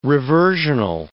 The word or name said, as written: reversional